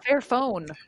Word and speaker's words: Fairphone: Ah, Fairphone. – Sí, bueno, tu, pronunciación es mejor.